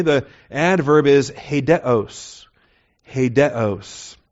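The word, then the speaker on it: Hedeos (ἡδέως): Hedeos, H-E-D-E-O-S, the long E followed by the short E, Eta, Delta, Epsilon, Omega, Sigma, beginning with the rough breather, which we often transcribe with the H in the English, H-E-D-E-O-S, the long O, the Omega. So it's Hedeos.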